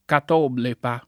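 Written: catoblepa

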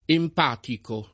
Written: empatico

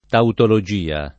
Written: tautologia

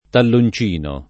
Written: talloncino

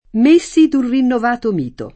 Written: messo

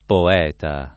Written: poeta

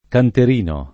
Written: canterino

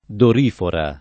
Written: dorifora

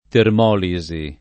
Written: termolisi

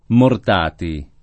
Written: Mortati

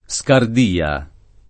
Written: Scardia

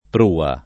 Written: prua

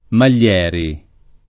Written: Maglieri

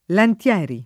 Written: Lantieri

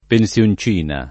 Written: pensioncina

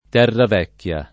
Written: Terravecchia

